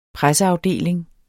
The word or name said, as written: presseafdeling